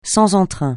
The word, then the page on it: sans entrain: francais